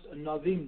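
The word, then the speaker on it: anavim (ענוים): Now the expression in Hebrew for a poor is usually aniim, but is pronounced anavim, the humble ones.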